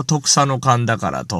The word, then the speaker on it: 十種神宝: で、あとは神様の宝と書いて、これを特佐の漢だからと読むわけでございます。